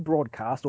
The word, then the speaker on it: Broadwcast: Well, it's pronounced broadcast or broadcast, as they say.